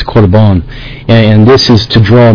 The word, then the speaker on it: Korban: No, I'm speaking of, I think it's Korban, or korban, as it's pronounced.